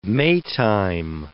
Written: Maytime

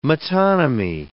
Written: metonymy

